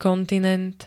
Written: kontinent

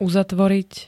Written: uzatvoriť

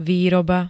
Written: výroba

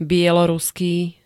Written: bieloruský

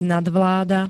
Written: nadvláda

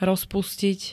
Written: rozpustiť

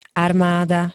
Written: armada

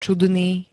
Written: čudný

